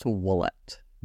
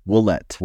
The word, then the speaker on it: Ouellette: It's actually pronounced wallet. Willette.